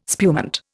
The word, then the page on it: spumante: Indian